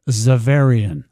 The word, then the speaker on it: Xaverian: We pronounce it Xaverian. – Xaverian. Oh, I would have never guessed. – It's named after our patron, St. Francis Xavier.